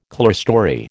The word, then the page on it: clearstory: American English